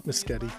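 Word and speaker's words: Mescudi: I assume his last name is pronounced Miscudi, right? – I would think that since his name is not Kid Cudi.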